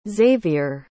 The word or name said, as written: zavier